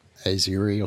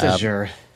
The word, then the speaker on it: Azure: Has anyone ever worked out, is there an official pronunciation guide? It's not Azuri or something? – It's just Azure.